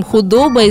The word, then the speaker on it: худоба: С ударением худоба известно меньше и имеет два значения.